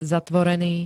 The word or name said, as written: zatvorený